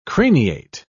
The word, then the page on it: craniate: British English